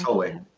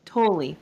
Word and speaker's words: Tolle: Am I saying that right? – Eckhart Tolle. – Tolle, thank you.